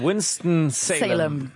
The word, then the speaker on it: Winston-Salem: Absolut, Winston-Salem.